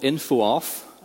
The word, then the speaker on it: infoof: Well, luckily there is some relief in the mythical info off, sometimes jokingly pronounced infoof operator. Now, the infoof operator, that's actually how the C-sharp team pronounces it.